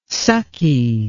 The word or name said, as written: sucky